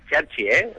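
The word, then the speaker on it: Cerci: Lo primero, yo diría que es Cherchi, pronunciado como si las dos fueran con CH, pero bueno, es igual, eso es lo de menos.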